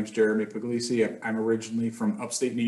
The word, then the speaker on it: Puglisi: With me today is Jeremy Puglisi the proper Italian pronunciation, I might add, uh, from New Jersey.